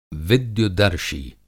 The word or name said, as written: विद्युतदर्शी